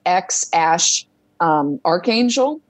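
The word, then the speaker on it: X Æ A-12: And it's supposedly pronounced like X Ash Archangel.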